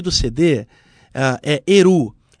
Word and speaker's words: Erhu: O nome do CD Eru se escreve E-R-H-U. Eru.